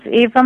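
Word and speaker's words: Ave: Now, let me just ask you before, is it Ava or Eva? – It's Ava Maria. So okay, because I kept saying Ava. I was trying to pronounce it very, you know, very wonderfully. – So that's all right. Ava Maria is fine.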